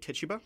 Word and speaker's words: Tituba: uh, The Crucible, where you played Tituba. Yes, that's. I'm, I'm, pronouncing it correctly.